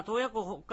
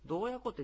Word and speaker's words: 洞爺湖: でこれはまあ、洞爺湖、北海道の中でも多分 1 番か 2 番ぐらい大きいんじゃないですか、湖の中で。結構大きいですよね。 – 広い。 – 5 本の指に入りますよね。 – そばで見たら海かなみたいな。 – で北海道の人はなぜか洞爺湖って濁って発音することがいいんですよね。